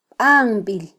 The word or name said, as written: banbil